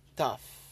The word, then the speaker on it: tough: Or tough. T-O-U-G-H. – Oh, tough. – You don't really pronounce the G or the H.